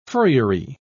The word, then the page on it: furriery: British English